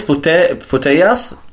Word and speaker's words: Putellas: Alex Putellas, so wird sie ausgesprochen, ist eine der besten Spielerinnen wahrscheinlich mit der Welt, hat auch Ballon d'Or und alles gewonnen.